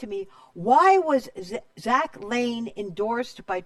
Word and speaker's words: Lahn: Is that how it's, well, it looks like Lahn. It's pronounced Lane. – Okay. – It's L-A-H-N, but okay. It's pronounced like Lane.